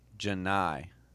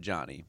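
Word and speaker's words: Johni: It is Janai Broom, not Johnny, but I still like Johnny better.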